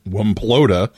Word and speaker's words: WMPLOTA: of WMPLOTA, or as I, I pronounce it, Wimplota, um, and some portable satellite experts.